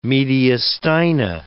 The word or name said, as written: mediastina